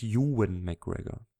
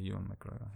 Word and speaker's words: Ewan McGregor: Und der heißt übrigens Ewan McGregor. – Ah, okay, Ewan McGregor. Ja, stimmt. Jetzt, wo ich sage, klingt's auch besser.